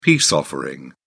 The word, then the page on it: peace offering: British English